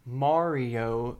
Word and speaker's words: Mario: Any Italian will tell you that the first name is pronounced Mario, the first syllable to rhyme with car.